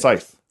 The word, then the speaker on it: Scythe: I'm going to pronounce it wrong, so you got to say it. – Scythe. Scythe. Scythe. S-C-T-H. – S-C-Y-T-H? – Yeah. No. – S-C. – Like the... – It's a scythe. – Swing blade? – S-C-Y-T-H.